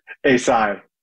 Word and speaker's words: Eisai: And Thyroid Head and Neck Cancer Foundation, Cancer Care, and how do you pronounce EISAI? One of you do that for me, Incorporated. I want to give everybody involved their props on this. – Yeah, it's ASI.